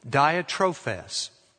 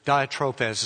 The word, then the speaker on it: Diotrephes: A man named Diotrephes. That's the best way to pronounce it.